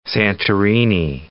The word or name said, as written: Santorini